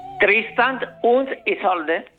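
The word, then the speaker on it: Tristan und Isolde: ¿Pero cómo se pronuncia en alemán? – Tristán und Isolda.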